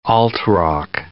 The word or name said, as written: alt-rock